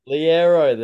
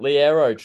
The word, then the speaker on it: Liero: Can I get a refresher on how to say his last name here? – Liero, there you go. – Like the Aero bar.